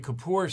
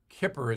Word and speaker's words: Kippur: I say Kippur sometimes, but it's Kippur is the correct pronunciation.